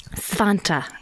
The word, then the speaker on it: Svante: Svante Arrhenius.